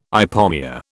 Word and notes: ipomoea: British English

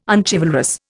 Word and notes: unchivalrous: Indian

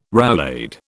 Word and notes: roulade: British English

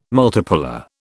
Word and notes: multipolar: British English

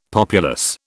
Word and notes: populous: British English

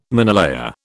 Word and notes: minelayer: British English

